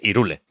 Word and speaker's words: Hyrule: O sea, Zelda dice Hyrule, como no lo voy a decir yo.